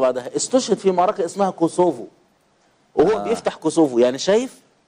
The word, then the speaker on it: كوسوفو: فإدرنا هذه هي العاصمة، خاض هذه المعارك واستشهد هنا في معركة قصوه، اللي هي يعني إيه كوسوفو، تنطق كوسوفو دلوقتي.